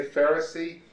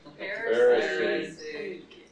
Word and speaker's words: Pharisee: Can everybody say Pharisee? – Pharisee.